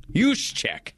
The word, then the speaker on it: Juszczyk: How do you say their fullback's name? Help me out here. Translation. – Juszczyk.